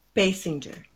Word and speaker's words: Basinger: Do you say Basinger or Bassinger? – Basinger, usually,